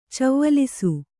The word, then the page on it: ಚವ್ವಲಿಸು: cavvalisu